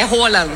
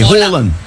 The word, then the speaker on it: Roland: A pronúncia dele, como é que fala o nome dele em norueguês? – É Roland. – Pronto. – Aí, Roland. – Roland.